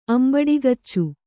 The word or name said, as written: ಅಂಬಡಿಗಚ್ಚು